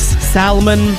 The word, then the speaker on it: salmon: F-O-L-K-S. I pronounced the L in a lot of things, I realized. Folks, Salmon. That's it.